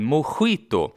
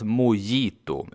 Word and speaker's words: mojito: Vi fortsätter på J-temat och ett annat vanligt ord som skandinaver ofta uttalar fel är ju drinken mojito. Det stavas ju med J i mitten där någonstans och därför ska det alltså inte uttalas mojito utan mojito. Samma sak här, vi lånar bara in sättet som vi säger första delen av svenska ordet skit, det vill säga vi lånar in ske-ljudet och sen så är saken biff.